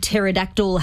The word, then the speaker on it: pterodactyl: The word pterodactyl has a silent P at the start.